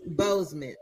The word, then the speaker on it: Boseman: Boseman.